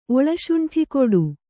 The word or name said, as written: ಒಳಶುಂಠಿಕೊಡು